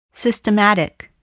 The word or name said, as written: systematic